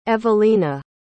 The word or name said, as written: evalina